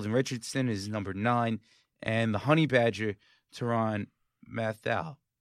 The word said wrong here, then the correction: Tyrann Mathieu: Right? Am I saying his name right? Tyrann Mathieu. Tyrann Mathieu. Thank you.